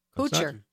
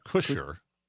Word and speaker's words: Kutcher: Kutcher. – It's not Kutcher. – No. – It's Kutcher. Okay.